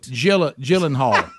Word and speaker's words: Gyllenhaal: Gyllenhaal. – Jake Giblet. – Gyllenhaal.